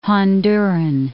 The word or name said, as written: Honduran